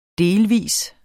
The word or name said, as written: Delvist